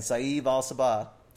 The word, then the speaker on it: Saeed Al-Sabah: and Saeed Al-Sabah. – Man, that was impressive. – What? – You fuck up white people name, but you got that? – Yeah. I've made sure I know how it's said.